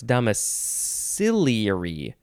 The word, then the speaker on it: domiciliary: No, it's domiciliary. Domiciliary. That's how you say it.